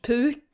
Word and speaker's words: Puke: Yeah, it's pronounced Puke, Erik Puke, but yeah, it's spelled like the English word for vomit, puke.